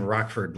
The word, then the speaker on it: Rochford: I'm told you pronounce his name Rockford like the city. It's spelled R-O-C-H-F-O-R-D.